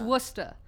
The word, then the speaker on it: Worcester: And if you're not from here, like, even I, one time in middle school, said Worcester, because that's how it's spelled. – But actually, but it's Worcester.